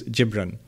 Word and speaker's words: Gibran: Not Gibran, okay, because I looked it up yesterday. The Arabic pronunciation is Gibran.